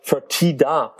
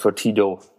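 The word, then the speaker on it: Fertitta: Fortida Brothers, so heißen sie, nicht Fortido, man kann das auch mal verwechseln.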